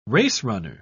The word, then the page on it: racerunner: British English